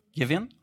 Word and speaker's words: Gibeon: Is that correct? Gibeon? Okay, perfect. Between Gibeon and Gilgal, there was 20 miles of distance.